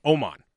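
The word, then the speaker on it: Åman: Oman.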